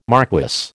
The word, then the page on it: marquess: American English